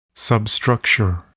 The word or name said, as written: substructure